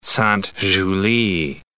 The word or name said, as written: Sainte-Julie